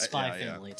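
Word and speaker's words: Spy × Family: But it's spy family, too. It's just it's a quirk of Japanese titling that the X is silent.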